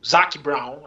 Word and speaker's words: Zac Brown: Eu, se eu fosse o Ricardo, eu faria o seguinte: falaria para o Zac Brown, agora eu falei certo, uhum.